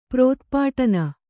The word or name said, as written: ಪ್ರೋತ್ಪಾಟನ